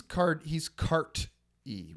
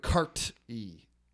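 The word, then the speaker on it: Carti: But he's Cart-E with a T. – Oh, okay. – And that's how it's pronounced. Playboy Cart-E.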